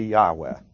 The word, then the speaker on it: Yahweh: With Yahweh, that is usually translated Lord, all in capital letters, or sometimes Jehovah. Correct pronunciation would be Yahweh, Y-A-H-W-E-H.